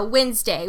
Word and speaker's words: Wednesday: That silent D will get you every time, man. So, Wednesday.